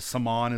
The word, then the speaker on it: Saman: Saman, is that how you pronounce it? Okay. – That's right.